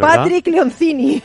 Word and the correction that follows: Lencioni: No, es Lencioni.